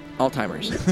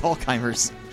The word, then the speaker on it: Alzheimer's: But now I just imagine Did you just say old timers? – Alzheimer's. Sorry. – It's Alzheimer's. – I think I did say old timers. – It's a weird pronunciation.